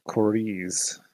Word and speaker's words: Korize: Captain Korize, K O R I Z E.